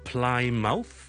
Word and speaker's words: Plymouth: 再嚟一个啦，plymouth，一个喺南边海边嘅一个城市，plymouth 就唔系读成咧，系 Plymouth， it's not Plymouth， but it's Plymouth， Plymouth。